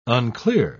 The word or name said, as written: unclear